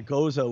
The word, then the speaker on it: Gose: It's spelled G-O-S-E, but it's a German word, so it's actually Goza is a proper pronunciation. – Goza.